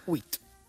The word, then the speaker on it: huit: Huit.